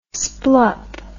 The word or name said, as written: splup